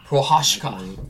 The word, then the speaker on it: Prochaska: Yeah, Yuri Prochaska. – Prochaska. – Yeah, it's a silent CH. It's pronounced Prochaska.